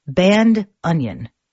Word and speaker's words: bandoneon: And a note on pronouncing bandoneon, if you don't speak Spanish, a friend of mine who plays this instrument, Emmanuel Trifilio, says you're also welcome to pronounce it like band onion. Band onion. Perfectly acceptable.